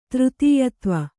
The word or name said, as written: ತೃತೀಯತ್ವ